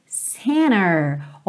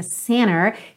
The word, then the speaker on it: center: Be with myself and center. Olha que interessante, super americana essa pronúncia center, que está realmente omitindo a letra T. Mas, se você quiser falar center, não tem problema nenhum.